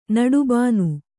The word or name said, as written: ನಡುಬಾನು